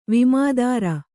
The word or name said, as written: ವಿಮೆದಾರ